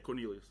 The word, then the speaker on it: Cornelius: Did I say that right? – Yeah, Cornelius.